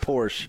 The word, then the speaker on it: Porsche: It's pronounced Porsche.